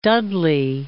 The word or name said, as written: Dudley